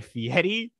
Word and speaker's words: Fieri: I wanted to focus on Guy Fieri, who joins the show.